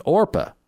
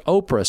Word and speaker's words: Orpah: She was actually named Orpah, but it was mispronounced Oprah so many times she just went with it.